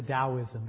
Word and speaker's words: Taoism: Well, let me introduce you a little bit to Taoism before my time is completely gone here. Sometimes it's spelled with a D. It's certainly pronounced with a D, Taoism.